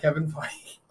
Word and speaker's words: Feige: Foggy. It's Foggy. – Okay, I don't know how to pronounce Kevin Foggy.